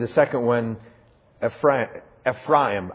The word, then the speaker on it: Ephraim: I'm trying to learn how to say that right. Ephraim. Craig Hartman insisted that we say it right this summer on the Holy Land tour. Every time I would say Ephraim, he would say, "Don't blaspheme my family name."